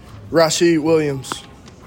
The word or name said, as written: RASHID WILLIAMS